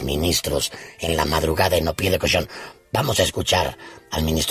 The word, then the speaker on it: Au Pied de Cochon: Que en Iguas, que no festejaron en Au Pied de Cochon. Lo dije bien, ¿verdad? Au Pied de Cochon.